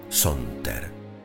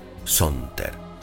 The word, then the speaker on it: saunter: Luego de mucho buscar, encontré la palabra Sonter. Se escribe S-A-U-N-T-E-R, Sonter, que cuando la leí me pareció a simple oído encantadora.